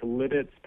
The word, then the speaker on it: Lititz: Is it Lititz? How do you pronounce it? – It's Lititz, Pennsylvania. – Lititz, Pennsylvania. – Yeah.